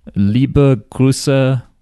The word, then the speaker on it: Liebe Grüße: Liebe Grüße, Kenny. How do you say that? – Good. What? You mostly got it. Liebe Grüße.